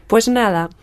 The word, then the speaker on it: pues nada: Pues nada.